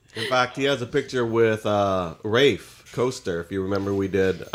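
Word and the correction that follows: Raph Koster: Raph Coaster.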